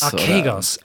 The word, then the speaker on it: Archegos: Wir, haben ja, wir haben ja eingangs jetzt hier schon mal über diese große Schieflage äh, eines äh, Hedgefonds von ähm, Archikos, wie, wie spricht man ihn nochmal Archigos? Archegos, Archegos. Also die, die Angelsachs haben Archegos, Archegos gesagt.